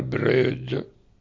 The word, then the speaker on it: Breud: Il Breud si scrive così in dialetto, ma si pronuncia il Breud.